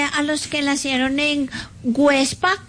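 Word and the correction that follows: Güepsa: Perdón, Huepsa. Websa. ¿Ah? Con diéresis.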